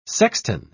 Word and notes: sextan: British English

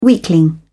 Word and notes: weakling: British English

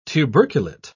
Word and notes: tuberculate: British English

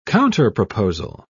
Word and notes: counterproposal: British English

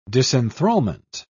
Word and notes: disenthralment: British English

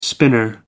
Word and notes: spinner: American English

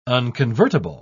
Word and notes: unconvertible: British English

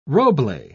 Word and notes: roble: British English

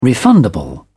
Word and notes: refundable: British English

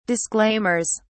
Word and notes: disclaimers: British English

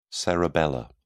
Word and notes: cerebellar: British English

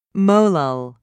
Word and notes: molal: British English

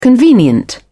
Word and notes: convenient: British English

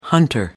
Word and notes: hunter: American English

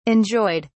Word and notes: enjoyed: British English

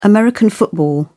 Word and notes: american football: British English

